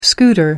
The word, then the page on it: scooter: American English